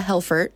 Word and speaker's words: Helfert: Alyssa Helfert, right? – Yeah.